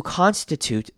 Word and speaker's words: constitute: 好，下一句 This is for them, those who constitute the true definition of an everyday hero. Constitute 这个字要特别注意，首先他看他 ST 这边的 T 我们要念成的声音，因为 ST 通常我们都会念成 st，所以这边 constitute 而不是 constitute，所以是 constitute。